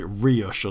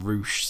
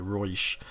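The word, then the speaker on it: Reusch: I'm wondering whether the correct German pronunciation was actually Ruche, but maybe I think. I think the only time I never had a clue. I always called it Rioche or something. But I think one of the kids at school actually had a pair, and he pronounced them Ruche. So that's the only one. I think you're probably right there, Ruche.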